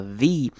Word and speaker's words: the: 看到第一个重点的是 the ability 的 the，这边念成长母音 e 的声音，因为下面一个字 ability 是一个母音声音开头的字，所以这边的 the 要念成 v 长母音 e 的声音。